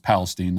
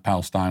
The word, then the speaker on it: Palestine: Thousands in East Palestine, pretty sure it's pronounced Palestine, not Palestine, although that's how it's spelled,